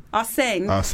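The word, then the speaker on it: Arsène: Arsene? Did I say that right? – Arsene. – Arsene.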